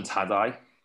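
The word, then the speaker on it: Tadej: Tadai. I learnt that. – Oh, yeah? Tadai. – Okay. He came on the GTCC podcast, my other podcast, Garen Thomas Cycling Club. And, yeah, that's how you say it anyway.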